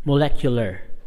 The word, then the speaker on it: Moleculer: It's spelled M-O-L-E-C-U-L-E-R. Molecular, not molecular.